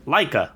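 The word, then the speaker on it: Laika: This second film here is from Leica? How do you say the studio name? – Leica.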